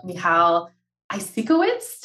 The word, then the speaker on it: Michal Eisikowitz: So Michal is the founder of Michal Eisikowitz.